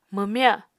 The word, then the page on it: mwmya: Apatani